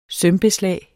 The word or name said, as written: sømbeslag